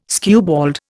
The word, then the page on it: skewbald: Indian